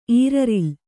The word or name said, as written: ಈರರಿಲ್